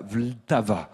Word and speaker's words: Vltava: Et la réponse, c'est la Vltava. Ça s'écrit V-L-T-A-V-A.